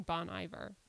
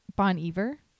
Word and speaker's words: Bon Iver: It's pronounced like different than Bon Iver. – Bon Iver.